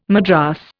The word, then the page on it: madras: Indian